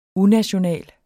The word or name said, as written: unational